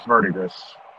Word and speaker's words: verdigris: Verdigris is the way we pronounce that here. Yeah. – It's verdigris. It's verdigris. – I know the river here in Kansas is pronounced verdigris. – Yeah, but you guys also pronounce everything else wrong. It's literally verdigris.